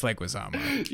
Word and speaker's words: Leguizamo: How do you pronounce it? Leguizamo? – It's Leguizamo.